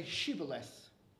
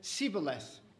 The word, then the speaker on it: Shibboleth: So the Gileadites would say Shibboleth. And the Ephraimites would say Sibboleth. And when they asked them, oh, you said it the wrong way, off with your head.